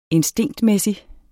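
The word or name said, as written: INSTINKTMÆSSIG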